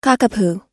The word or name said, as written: cockapoo